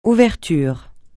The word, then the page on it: ouverture: francais